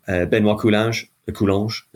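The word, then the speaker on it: Benoît Coulanges: Benoit Coulanges, as he's pronounced in French, is due a win now, it looks like.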